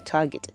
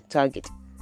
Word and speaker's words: target: So associate this word with gate so that anytime you want to pronounce it, remember it is G. The G in gate is what you have in target, target.